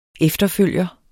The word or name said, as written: efterfølger